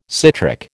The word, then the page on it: citric: American English